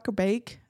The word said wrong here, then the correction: Bakke: I can't pronounce that name. – They typically pronounce it. I mean, it's not like I knew the person, but I think they typically pronounce it as Bakke.